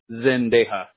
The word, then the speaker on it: Zendejas: And that was D-E-D like David? – Yeah, D like David, E-J-A-S. – Got it. So Miguel Zendas, did I say it right? – Zendejas, yeah.